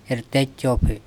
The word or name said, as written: erte cOpHe